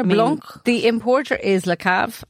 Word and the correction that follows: Le Caveau: Okay. And this particular wine is a blend. – Le Caveau, you mean? – Who did I say?